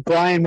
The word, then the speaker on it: Brian: So it's, my first name is Brian with an I, B-R-I-A-N. And then last name is Hartzman, H-A-R-T-Z-M-A-N.